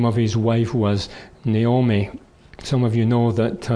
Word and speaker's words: Naomi: They called her Naomi. The emphasis on the first syllable, rather than on the second syllable, Naomi.